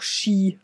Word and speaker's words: Ski: Und eben, es hat sich auch, jedenfalls bei uns im Deutschen, die norwegische Aussprache durchgesetzt, weil die schreiben im Ski, aber sagen auch Ski, weil das ist eigentlich mit SK ist da immer oder meistens jedenfalls Sch.